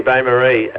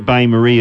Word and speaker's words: bain-marie: How would you pronounce it? – I've eaten enough sausage rolls out of them, but... Bain-Marie. It's. – I would pronounce it Bain-Marie as well, but it's a slightly misleading. – No. So we're not spelling it as it sounds, are we? – No.